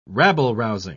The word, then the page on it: rabble-rousing: British English